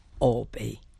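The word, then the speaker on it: Albee: And by the way, Mr. Olby's name is pronounced as though there are two L's when there is, in fact, only one. It's Edward Olby.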